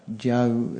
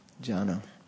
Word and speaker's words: Jana: Again, not Jau, it's Jana.